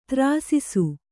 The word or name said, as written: ತ್ರಾಸಿಸು